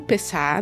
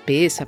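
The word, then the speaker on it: Pessach: Porque os judeus comemoram o Pessá, né? Pessa, Pessá, como você preferir a pronúncia.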